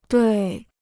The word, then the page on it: 对: duì